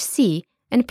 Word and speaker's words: can: Firstly, J, which is written like the English C and pronounced like J. An example is Can, which means soul. Turkish J is never read like K or S, like in English.